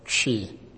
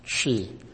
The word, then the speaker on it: Twi: However, the most predominant one is called Chi. Chi. And we spell it T-W-I. But to help you spell it, like C-H-W-I. Chi.